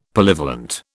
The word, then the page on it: Polyvalent: British English